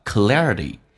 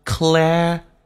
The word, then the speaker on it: clarity: 第一个单词很多人喜欢重音放在第二个字母上，叫 clarity。 clarity 这事实上是有问题的，它重音在第一个 C 上，叫 clarity。